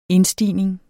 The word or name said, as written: indstigning